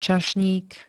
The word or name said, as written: čašník